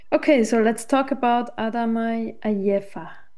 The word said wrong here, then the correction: Adama Ayefa: It's pronounced Adama Ayefa.